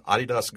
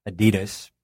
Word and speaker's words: Adidas: It's so common, though, to hear it in the U.S., Adidas, but properly pronounced, especially if you're ever in Germany, you're going to hear it Adidas.